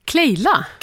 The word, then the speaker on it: Clélia: Jag vet inte om jag ska uttala hennes namn, det är franskt. C L E med en apostrof över E. L I A. – Kleja eller Kleila. – Kleila.